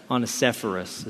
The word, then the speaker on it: Onesiphorus: Onesiphorus is how you pronounce it.